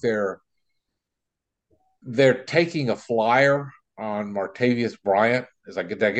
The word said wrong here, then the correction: Martavis: get did I get his name right? Because no BS. It's Martavis.